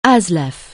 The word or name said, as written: ASLEF